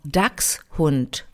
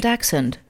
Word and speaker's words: Dachshund: Das ist ein deutsches Wort und nur englisch ausgesprochen. Dachshund. – Ein Dachshund, ja. – Die witzig. Sagen Dachshund.